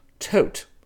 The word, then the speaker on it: Tod: The name is spelled T-O-D, but in German it's pronounced Tote, as in tote that bell."